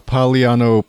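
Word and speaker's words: Paliano: Yeah, on your Twitter feed, I'm seeing a bunch of newspapers from the Pagliano Post. Did I pronounce that correctly? – Yes, you did.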